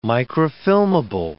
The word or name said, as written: microfilmable